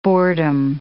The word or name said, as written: boredom